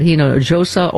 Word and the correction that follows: Hinojosa: You pronounced the J. – Don't pronounce that. – Pardon me. – It's an H sound. – Pardon me. – Like San Jose.